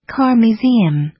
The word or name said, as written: car museum